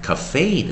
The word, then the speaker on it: cafe: 但是少数也是有可能发音，像 cafe 的那个 e 是要发音的哦。